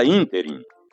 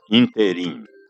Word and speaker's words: ínterim: A palavra ínterim é proparoxítona e, portanto, com acento gráfico na antepenúltima sílaba, e não oxítona, como ouvimos por aí, interim. Interim não existe.